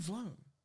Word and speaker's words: VLONE: V-Loan Friends? V-Loan. Yeah. Huh? V-Loan. Vloan. Is that V-L-O-N-E? – Yeah. – Vloan. – That's how you say it? That's crazy. I've been saying V-Loan all my life.